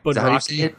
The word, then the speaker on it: Bunraku: bunraki. Bunraku. It's it's it's it's Bunraku. – Bunraku. Okay. – Yes, according to the Webster's uh, online dictionary, where you can type in a word and it will actually pronounce the word for you. – Bunraku. The the voice pronounced it as Bunraku.